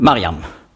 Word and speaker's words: Mariam: Und darum in alttestamentlicher Zeit hat man nicht ausgesprochen Miriam, wenn es geht um die Schwester von Mose, sondern man hat das ausgesprochen Mariam. Aber sprachgeschichtlich kam dann eben diese Änderung in den Jahrhunderten nach Christi Geburt zu Miriam.